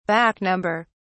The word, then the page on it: back-number: British English